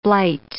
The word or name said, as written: blight